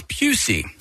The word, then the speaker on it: Pusey: But that same year, that same teacher, that same class, there was a story that we were reading out loud, and one of the characters' name was Pusey, and we pronounced it not that way.